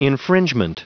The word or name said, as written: infringement